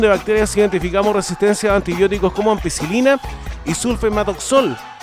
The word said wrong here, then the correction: sulfametoxazol: Lo dije bien, ¿no? Sulfametoxazol. Ahí está.